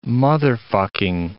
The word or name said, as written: motherfucking